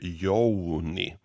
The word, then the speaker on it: Jouni: För att ska man säga mitt namn på det riktiga sättet så är det Jouni. Och de här ah. – vokalerna O och U finns inte i svenskan.